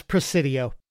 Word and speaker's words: Presidio: Am I saying that right? – It's Presidio. – Presidio. Okay. Maybe you should read this one, but we're already into it.